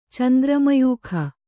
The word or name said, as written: ಚಂದ್ರಮಯೂಖ